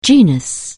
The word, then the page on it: genus: anglais